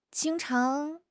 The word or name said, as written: jing1 chang2